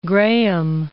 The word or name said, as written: graham